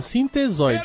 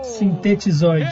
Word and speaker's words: sintetizoide: Não, ele é um sintesoide. Errou! Sintetizoide.